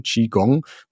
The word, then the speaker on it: Qigong: Tatsächlich, Qigong wird eigentlich immer dieses Ch, Ch ausgesprochen. Qigong.